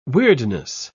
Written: weirdness

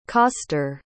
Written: coster

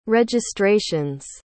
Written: registrations

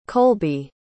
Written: colby

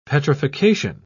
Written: petrification

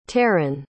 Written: terran

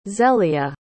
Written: zelia

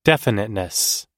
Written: definiteness

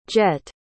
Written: Jett

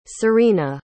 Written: serena

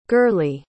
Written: gurley

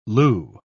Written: loo

